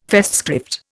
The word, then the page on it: Festschrift: Indian